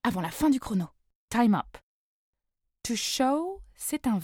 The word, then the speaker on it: time: Time, qui se prononce time, super bizarre, égale temps.